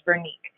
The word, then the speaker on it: Vernique: So my grandfather's name is Vernique, but it's pronounced the same in French, Vernique. So yeah,